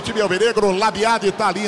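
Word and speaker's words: Labyad: Tem uns caras que falam Labiá, a pronúncia é Labiade ou Labiá, hein? – Marroquinamente falando, Marroquinamente. – Labiá. Ah, meu Deus. – Portuguesamente, Labiade. – Então tá bom. Vamos no português, né? Labiade.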